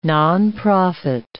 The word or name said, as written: nonprofit